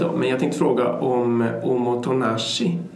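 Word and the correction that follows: Omotenashi (おもてなし): Omotenashi. – Omotenashi.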